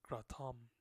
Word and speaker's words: Kratom: In Southeast Asia, it's actually pronounced Kratom. Kratom, not Kratom.